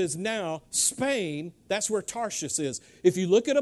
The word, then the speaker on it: Tarshish: I had to work hard to pronounce that correctly. Tarshish, because I kept thinking of Saul of Tarsus, okay? So it kept messing me up.